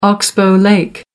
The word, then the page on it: oxbow lake: American English